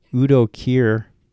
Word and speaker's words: Udo Kier: In Volume 2, we have most of those same people, plus Jamie Bell, Willem Dafoe, Mia Goth, and Udo Kier. Is that how you pronounce his name? – I believe so.